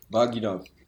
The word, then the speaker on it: Vagina: Wie glaubt ihr, wird das Wort Vagina ausgesprochen? Wo ist die Betonung? Auf war, auf gi oder auf na? – Auf gi. – Vagina.